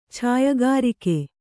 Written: ಛಾಯಗಾರಿಕೆ

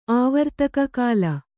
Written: ಆವರ್ತಕ ಕಾಲ

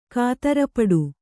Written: ಕಾತರಪಡು

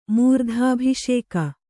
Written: ಮೂರ್ಧಾಭಿಷೇಕ

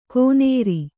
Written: ಕೋನೇರಿ

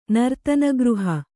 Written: ನರ್ತನಗೃಹ

